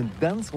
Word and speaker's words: Gdańsk: Alors d'abord, comment prononce-t-on bien ? Ça s'écrit G-D-A-N-S-K. On dit Gdansk ou Gdansk, on prononce le G ou pas?